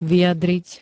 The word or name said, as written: vyjadriť